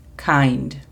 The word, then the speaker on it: kind: La pronunciación fonética es K-A-I-N-D, kind. Second, segundo, es little. Little significa pequeño. La pronunciación fonética es L-E-D-U-L, little.